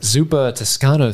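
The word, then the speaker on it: Zuppa Toscana: your, I'm going to mispronounce it, Zuppa Toscano soup. – That was well done, I feel like.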